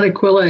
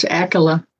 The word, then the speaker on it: Aquila: I call him Aquila. It's not Aquila, it's Aquila. – Okay. – According to my pronunciation Bible.